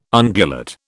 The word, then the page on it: ungulate: British English